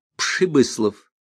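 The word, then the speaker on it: Прибыслав: Интересно было то, что буква «Р» в этом имени произносилась как «Ш» — Пшибыслов.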